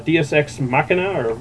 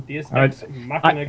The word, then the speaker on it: Deus Ex Machina: From Deus Ex Machina or Machina? – Oh, yeah. No, you got it right the first time. – Deus Ex Machina Games.